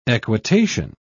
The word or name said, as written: equitation